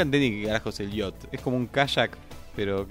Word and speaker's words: yacht: Que se escribe Jacket. Yacht. Y se dice yacht.